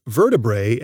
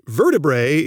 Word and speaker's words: vertebrae: I've pronounced vertebrae as vertebrae. That's because, in general, when you have a scientific word ending with the vowels A-E, you pronounce them as a long E, like the word algae, or like the many bird families I've talked about on the podcast.